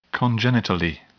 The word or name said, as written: congenitally